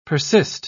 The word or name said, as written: persist